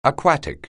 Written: aquatic

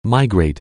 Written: migrant

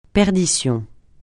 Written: perdition

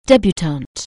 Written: debutante

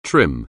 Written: trim

